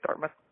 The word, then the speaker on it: Dartmouth: Dartmouth? – Yeah.